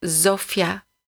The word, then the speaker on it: Zofia: Un Z seul se prononce Z. – Zofia.